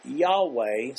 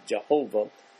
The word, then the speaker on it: Yahweh: Here, Elohim is combined with the Hebrew word Yahweh, some pronounce it Jehovah, and it's translated Lord.